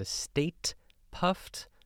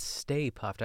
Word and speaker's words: Stay Puft: I always used to call it when I was younger, the State Puft Marshmallow Man. – Mm-hmm. – That's like something I've been mispronouncing all my life. But it's Stay Puft.